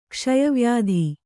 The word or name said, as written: ಕ್ಷಯವ್ಯಾಧಿ